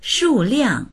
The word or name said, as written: shu4liang4